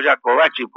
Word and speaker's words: Jacobacci: Yo le digo Jacobacci porque se pronuncia así.